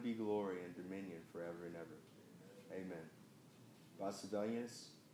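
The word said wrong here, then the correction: Silvanus: am I pronouncing that right? – Sylvanus, yes. – Yeah, correct. Sylvanus.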